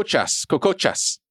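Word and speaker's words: kokotxas: It's Coco Cocochas. The TX is pronounced as a CH in English from Aaron Bilbao, who's uh, ends her comment with, My hubs is from Bilbao.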